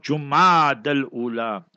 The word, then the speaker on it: Jumad al-Ula: and that will usher in the month of Jumad al-Ula.